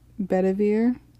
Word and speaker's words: Bedivere: And then he asks one of his men, Sir Bedivere. I'm probably saying that wrong too. – Sounds right to me.